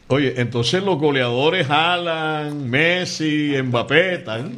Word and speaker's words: Haaland: Yo escuché hoy a un amigo dominicano que estudió en Noruega y dijo que ese apellido se pronuncia Haaland. – Haaland.